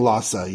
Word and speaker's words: Lhasa: Yasa? Lhasa? – I was saying Lhasa, yeah.